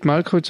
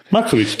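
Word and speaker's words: Markovics: Nicht Malkowitz, sondern Markowitz, glaube ich. – Markowitz, doch, habe ich richtig gesagt.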